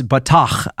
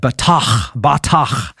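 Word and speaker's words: batach (בָּטַח): The Hebrew word here for the word trust is spelled B-A-T-A-H, transliterated, but it's batach. I can't say it right because you really got to get that girth in there, that batach.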